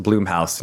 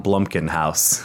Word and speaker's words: Blumhouse: Also, it's pronounced Blumhouse, not Blumkin House.